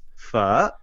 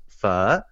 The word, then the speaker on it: pho: I've heard it all, but it's pho. That's the actual dialect of how to say it. There's accents on the end. So there's a little bit of like a rise and fall to the naming of it. It's pho.